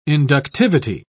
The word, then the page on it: inductivity: British English